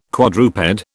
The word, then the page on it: quadruped: British English